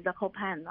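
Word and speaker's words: Zakopane: 然后而且再加上就是说这个城市叫做 Zakopan，那我们一般国内有人翻成扎可潘宁，因为它那个 Pan 后面的 NE 有些发音有些不发音，然后我们一般是称它为 Zakopan 哦。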